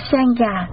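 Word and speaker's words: Sangha: Tăng nguyên là tăng già, tiếng Ấn Độ đọc là sen già, dịch nghĩa ra chữ Hán là hòa hợp chúng.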